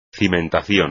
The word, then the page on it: cimentación: espagnol